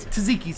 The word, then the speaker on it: tzatziki: Yeah, there is a correct way to pronounce it. – Yeah. – It's tzatziki sauce.